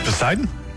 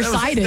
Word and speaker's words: Poseidon: A not silent P that I feel like should be silent. You don't have to say... – He would just be Poseidon? – Yeah. Instead of Poseidon or Sidon.